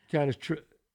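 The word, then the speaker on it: Cannistraci: Yeah, is that how you say it? Because I don't want to say it wrong. – It's Canastrasi. – Canastrasi. How do you say it? – I believe it's Canastrasi.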